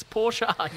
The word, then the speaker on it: Porsche: It's Porsche.